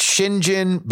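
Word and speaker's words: St John: The name St. John is pronounced Shinjin because Brits.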